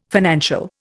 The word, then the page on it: financial: Indian